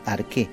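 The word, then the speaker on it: arqué (ἀρχή): La palabra principado en griego es la palabra arché, que se pronuncia arqué, y significa principio, origen o diseño.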